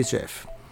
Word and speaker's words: Scheff: Sheff se escribe S-C-H-E-F-F. Pídalo así, Jerry Sheff.